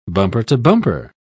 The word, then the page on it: bumper-to-bumper: British English